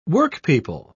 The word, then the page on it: workpeople: British English